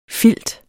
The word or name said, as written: filter